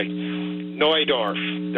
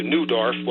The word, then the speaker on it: Neudorf: Like, for example, I think you mentioned the place northeast of Regina, Neudorf, that would be the proper pronunciation, but somehow it's wandered into Neudorf. Well, it's not spelled N-E-W, it's spelled N-E-U.